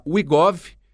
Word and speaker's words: WeGov: É o André, que é fundador e diretor executivo da WeGov, W-E-G-O-V, tá certo, André? É isso mesmo? – É isso mesmo, pronúncia perfeita.